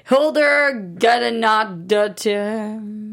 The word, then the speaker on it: Hildur Guðnadóttir: Hildur Guðnadóttir.